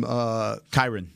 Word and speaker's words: Kyren: Is it Kyren Williams from Kyren.